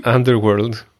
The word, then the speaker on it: Underworld: Πώς προφέρετε ρε? Underworld. – Underworld.